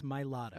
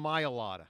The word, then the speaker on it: Mailata: There's no Maya. It's Mailata. – I always call him Mailata. – I know you do. – So I should call him Mailata? – Mailata.